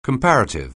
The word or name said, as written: comparative